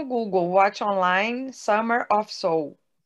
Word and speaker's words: Summer of Soul: Gente, é Summer of Souls, desculpa, falei errado. – Summer of Soul, é, de alma, é.